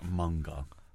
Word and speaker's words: manga: I believe manga? It is pronounced manga.